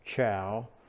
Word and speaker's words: Chou: I think it's pronounced Chu. Most people pronounce it Chow.